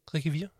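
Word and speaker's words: Riquewihr: Ricuvier, ja det var meget godt udtalt.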